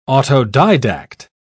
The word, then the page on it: autodidact: British English